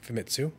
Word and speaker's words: Famitsu: Did I say that right? – Famitsu. – Famitsu. Excellent. Um, where, where they came out and they said, you know, it's all of the models needed to needed to be redone.